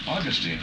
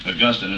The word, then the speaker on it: Augustine: And so in England, apparently they pronounced that name Augustine instead of Augustine, although it's spelled exactly the same.